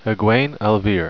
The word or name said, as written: egwene